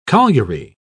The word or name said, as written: colliery